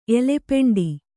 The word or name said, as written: ಎಲೆಪೆಂಡಿ